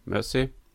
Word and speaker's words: Mersey: Und in Anlehnung an den Fluss Mersey, oder Mersey, wie würdest du so aussprechen? – Genau so. – Mersey.